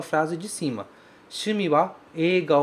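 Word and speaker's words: ega (映画): Cuidado com essa palavra ega, porque ela se escreve ei, mas ela se pronuncia e. Ok?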